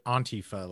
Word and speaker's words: Antifa: I pronounce it Antifa, like aunt, like she's your auntie.